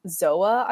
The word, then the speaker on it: Xoa: called Zoa, I believe. It's spelled X-O-A, which came out in 2014, and it included new songs and old songs.